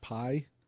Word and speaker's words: Pi: And the team is led by, I pronounce it Pi. It's P-I.